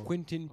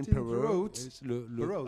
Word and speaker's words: Quentin: Alors moi, là-bas, je m'appelle Quentin Perot. Quentin, Quentin alors on me demandait comment est-ce que tu dis Tu dis Quentin.